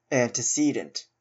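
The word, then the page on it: antecedent: Canadian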